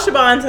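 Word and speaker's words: Siobhan: Okay, spell Shabon so they know. – Oh, okay. So it's spelled S-I-O, B like boy, H-A-N-S, then S-O-L-U-T-I-O-N-S. So ShabonSolutions.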